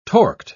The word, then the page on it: torqued: British English